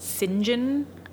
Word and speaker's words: St. John: John, which was for some reason pronounced St. John.